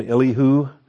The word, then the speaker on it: Elihu: Is this Elihu? I think I've called him all my life. I've called him Elihu. I think I'm going to start calling him Elihu. Rhymes with anywho.